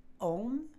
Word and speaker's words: Om: so we start with Om, which is A U M. It's pronounced A U M. You see it as O M.